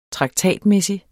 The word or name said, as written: traktatmæssig